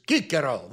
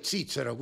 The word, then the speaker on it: Cicero: No sel kuradi aastal 106 enne issand aasta hakkamist sündis Marcus Tullius Cicero või Cicero, kuidas tahate hääldada.